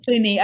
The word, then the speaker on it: Pliny: So thinking about, I always pronounce his name wrong, but Pliny the Elder. – I say Pliny, but... – Pliny, okay, let's do that.